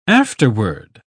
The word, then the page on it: afterword: British English